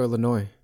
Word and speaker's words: Illinois: And do you pronounce the S at the end of Illinois? Or do you say Illinois or Illinois? – I think it's Chicago, Illinois. I think it's just Illinois. – Okay. – Yeah, I don't think I've ever. – Silent S.